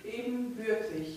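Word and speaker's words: ebenbürtig: Want ja, men moet wel eben geboortig geweest zijn. Dat. In Duits zeg ik dat goed, zuster? Geboortig? – Ebenbeurtig. – Ebenbeurtig. Oh, ge hoeft er niet tussen. Ebenbeurtig, oké. Okay.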